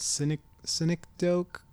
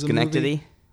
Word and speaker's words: Synecdoche: Synecdoche, New York? – Was Schenectady? – It's not Schenectady. – It's Schenectady. Schenectady. Schenectady, New York. S-Y-N-E-C-D-O-C-H-E. – Yes. The Charlie Kaufman movie? – O-C-H-E.